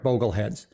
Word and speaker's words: Bogleheads: Bogle is spelled B-O-G-L-E. So it's Bogleheads, B-O-G-L-E. Go ahead. – Correct, Bogleheads.